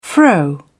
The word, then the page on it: fro: British English